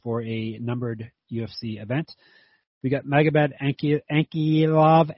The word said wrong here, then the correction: Ankalaev: Ankilov, Ankilov. Why can't I say his name? An- Ankalaev. – Ankalaev.